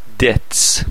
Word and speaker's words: debt: debts debt یعنی قرض بیش تلفظ نمیشه he has large gambling debts debt دوباره بی تلفظ نمیشه